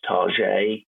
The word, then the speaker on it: Target: Well, he's a performer and a director, but I think he just prefers it to be pronounced Target.